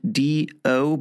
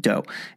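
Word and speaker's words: Dō: And that, by the way, is spelled D-O, but with a line above the O called a macron, which makes the O have a long sound, so it could look like do, but it's actually pronounced dough.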